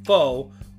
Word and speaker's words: pho: What is, it's spelled P H O pho, but it's pronounced pho.